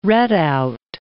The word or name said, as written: redout